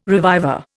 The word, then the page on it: reviver: Indian